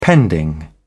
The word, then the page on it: pending: British English